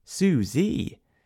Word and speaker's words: Su-Z: Suzette is a personal assistant software, spelt S-U-Z. It's meant to be pronounced Suzie, but Kimmy didn't realise this initially, and now she prefers her own pronunciation.